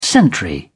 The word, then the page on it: sentry: British English